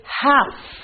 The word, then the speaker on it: half: So when you say the word half, L is silent.